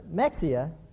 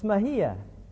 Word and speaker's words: Mexia: It's not Mexia. It's Mejia, 28 miles. Passenger says, there's no way it's Mejia. It's spelled M-E-X-I-A, Mexia. There's no double L that is silent. There's no H in that word at all. It's Mexia, 28 miles. He said, no, it's not. Down here in south, especially along the border, it's Mejia, 28 miles.